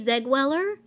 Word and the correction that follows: Zellweger: How do you say her name? – Zellweger. – Oh, Zellweger. Oops.